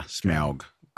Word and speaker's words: Smaug: Yeah, Smaug.